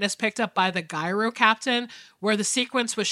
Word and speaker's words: Gyro: The most alarming part was when Max gets into an accident and is picked up by the Gyro Captain. Did I say that right? – Yeah. – Gyro, it's not Euro, certainly.